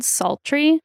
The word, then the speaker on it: Psaltery: It's called a Bode Psaltery. Like sultry is spelled like Psaltery.